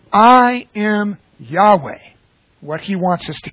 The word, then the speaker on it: Yahweh: So what we're really focused on is that core of those four consonants, YHWH, proper pronunciation, Yahweh.